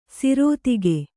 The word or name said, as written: ಸರಿದೂಗು